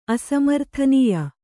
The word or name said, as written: ಅಸಮರ್ಥನೀಯ